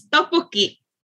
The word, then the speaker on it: tteokbokki: is it hiokbokki? Am I saying that correctly? – Close. Tteokbokki. – Say it again. – Tteokbokki. – Oh, so bokki. – Yeah, tteokbokki.